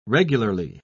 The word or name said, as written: regularly